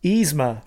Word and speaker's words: Yzma: Yzma? – Yzma.